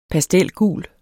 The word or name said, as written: pastelgul